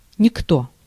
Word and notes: никто: /nʲɪˈkto/

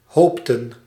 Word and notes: hoopten: /hɔʊptə(n)/

